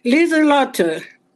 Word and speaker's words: Lieslotte: Lieslotte.